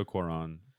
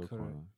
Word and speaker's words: Cuarón: Anyways, from a cinematic standpoint, so Alfonso Cuarón, I don't pronounce his last name. Alfonso Cuarón. Cuarón. Cuarón. – C-U-A-R-O-N.